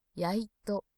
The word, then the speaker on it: やいと: れども、ハリやイトだったらね、ハリとイトのことでそんなの何のことかわかりますかって聞かれるわけがないと思ったので、じゃあ何だろうと思ったら、関西弁ではお灸のことをやいとというので、針とお灸、鍼灸のことを針、やいとというと教えていただきました。で、発音は背広やおかずと同じ要領で読む、全体的にプレーンに読むと思います。